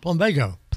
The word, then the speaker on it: Plumbago: How do you pronounce this? Plumago. – Plumbago? – Plumbago. – Plumbago? – Plumbago. – Plumbago? – Right.